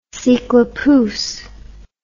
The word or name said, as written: cyclopousse